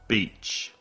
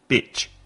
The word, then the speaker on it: beach: Beach. Vowel sound, sonido vocal, E. Repeat. Beach. No olvides hacer el sonido vocal largo. E. Beach. Si no, suena como bitch, que es una perra.